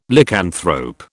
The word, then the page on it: Lycanthrope: British English